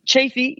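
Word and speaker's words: Chaffee: How do you like Chafee County? And am I pronouncing it right, Chafee? – Yeah, Chafee.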